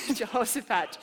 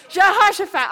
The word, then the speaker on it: Jehoshaphat: His name is Jehoshaphat. Everybody says it differently, but that's how I pronounce his name. Jehoshaphat. Jehoshaphat.